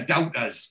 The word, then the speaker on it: doubter: And Oz, he says, aye, she'd better lock up your doubters. That's a proper Geordie pronunciation, that doubter.